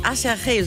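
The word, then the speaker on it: Aserejé: Las Ketchup Song von einer Combo namens Asherje, so wird die ausgesprochen, aber sieht aus wie Acherje, so kann man sich das auf jeden Fall gut merken.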